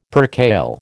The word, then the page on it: percale: American English